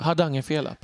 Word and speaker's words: hardangerfela: Och dessutom ackompanjerat av The King of the Golden Hall, det här Rohan-temat som jag med, eh, hur uttalas det? Hardangerfela? Hardangerfela, precis.